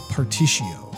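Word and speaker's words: Partitio: I think the way you pronounce the merchant's name is Particio. There's an R in there, I want to say.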